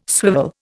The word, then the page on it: swivel: Indian